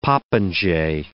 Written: popinjay

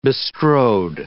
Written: bestrode

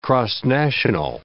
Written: cross-national

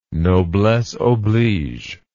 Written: noblesseoblige

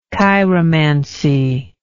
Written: chiromantical

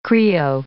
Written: Krio

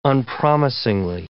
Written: unpromisingly

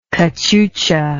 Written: cachucha

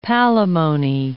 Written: palimony

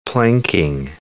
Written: planking

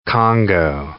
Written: Congo red

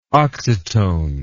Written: oxytone

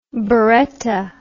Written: baretta

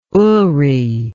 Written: oory

